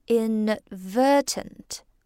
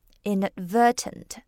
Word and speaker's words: inadvertent: 这个词啊，在发音上大家要注意一下，它的重音落在第三个音节。 inadvertent 无意的 inadvertent 好，那无意的伤害就是 inadvertent harm，那无意的冒犯就是 inadvertent offense。